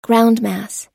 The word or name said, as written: groundmass